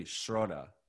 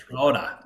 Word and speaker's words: Schroeder: I think it's technically Schroeder. Like, Schroeder. You gotta start saying that. Yeah. Because you're the only one that would say it right. Because every time I listen, it's a different pronunciation.